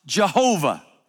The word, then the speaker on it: Jehovah: The other is a pronunciation that takes the vowel points from the Old Testament word Adonai and adds them to the letters that we get the word Yahweh from, and it's pronounced Jehovah. Yahweh or Jehovah are two English pronunciations of this same Hebrew root name that is the name of God.